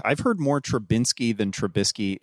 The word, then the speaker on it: Trubisky: It's Trubisky.